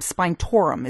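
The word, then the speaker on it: spinetoram: but it's like spine, like the back of your spine, and then torum, spine torum is the name of the chemical.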